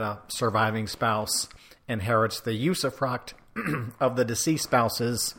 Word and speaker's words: usufruct: It's called usufruct.